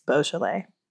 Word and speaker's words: Beaujolais: I said Beaujolais because I thought it was bougie, but it's Beaujolais.